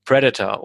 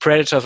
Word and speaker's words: Predator: Oder wie Kinder, wie ich es damals waren, nicht Predator, sondern Predator gesagt haben. Man hat es damals mal falsch ausgesprochen in Deutschland. Predator mal gesagt. Aber er heißt tatsächlich Predator, das Haupttier. Ja, also immer Predator, ne, weil das immer die Silbe, die erste Silbe falsch betont.